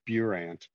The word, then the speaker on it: Burandt: There's a little U in there, so it's Burandt.